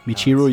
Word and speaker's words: Michiru: Michiru no, Yamane.